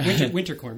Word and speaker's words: Winterkorn: Was it Winkelhorn? Not necessarily. Anyway, and I just said his name wrong. What's his name? Outgoing. It doesn't matter. – Yeah. Winterkorn. Martin Winterkorn. – Winterkorn. There it is.